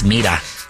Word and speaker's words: Mira: No, that's Mira. That's Mira. – But it's spelled like that.